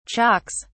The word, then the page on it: chalks: British English